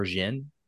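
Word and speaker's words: jinn: I actually prefer Jin. So I'm just throwing out there. – D-G-I-N-N? – I think it's J-I-N. – The Jin, right? – Yeah. – The Jin. That's how they say it in Witcher.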